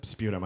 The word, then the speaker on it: sputum: Sputum, I guess. – Oh, whatever, Mr. Technical. – Oh, I'm saying it wrong. – A what? – I was pronouncing it wrong.